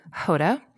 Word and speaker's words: Jota: which is Hoda, which is the way that we pronounce Jota, the letter J in Spanish.